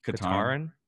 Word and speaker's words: Katarn: There's I can't pronounce his last name, but it's Kyle Katarn. Yeah. Okay, yeah.